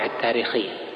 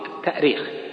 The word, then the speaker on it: التأريخ: طبعا من حيث الأصل الاشتقاقي التأريخ، التأريخ بالهمز لأنها أرخ يؤرخ، الهمز أصلي فيه، أرخ يؤرخ تأريخا. وأما التاريخ فهو تسهيل،